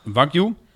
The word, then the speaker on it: Wagyu: Nee, je hebt nog eh, eh, eh, Wagyu, W-A-G, uh, Griekse I-U. Dat is heel duur, hoor ik. Dat is inderdaad heel duur spreek je uit als wagyu. Want wa betekent Japan en gyu betekent koe.